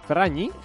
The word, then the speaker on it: Ferragni: Es como una ñ. Ferragni.